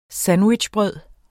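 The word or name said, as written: sandwichbrød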